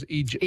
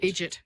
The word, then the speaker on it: idiot: And in the southern states in America, idiot becomes idjit. – Idjit, exactly.